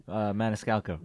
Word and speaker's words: Maniscalco: You ever listen to comedian Sebastian Maniscalco? Excuse me, I think I'm pronouncing his name wrong. – Maniscalco. – Maniscalco.